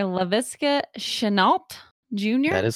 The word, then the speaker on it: Laviska Shenault Jr.: Laviska Shenault Jr. That is correct.